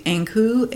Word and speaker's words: Ankou: Brittany-ish language, which and it's the Ankou, A N K O U, mm-hmm.